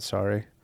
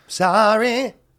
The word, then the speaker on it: sorry: You know, By the way, the YouTube comments are going to be lighting up with how you pronounce sorry. – Sorry. That's better, right? – No, you say sorry.